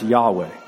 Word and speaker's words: Yahweh: Now, I am in Hebrew is pronounced Yahweh.